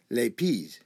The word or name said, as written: lapiz